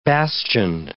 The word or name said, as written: bastioned